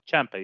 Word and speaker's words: Ciampa: Ciampa, yes.